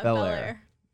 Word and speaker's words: Bel Air: You're a senior from Bel Air, Texas. Did I pronounce that right? – Yeah, Bel Air.